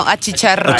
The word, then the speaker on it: achicharrar: Te puede achichonar, a, achichonar. – No, achicharrar. – achicharrar.